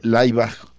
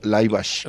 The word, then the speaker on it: Laibach: Alle Gegen Alle se llama esta marcha semimilitar de Laibach o Laibach. – Laibach. – Como lo quieran pronunciar en alemán o en esloveno.